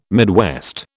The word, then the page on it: Midwest: British English